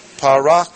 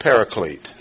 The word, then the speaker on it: parakletos: The word for advocate is one that, if you probably know no other Greek word you've heard, parakletos. And it's popularly pronounced paraclete. That's as far from the pronunciation of the Greek word as you can get.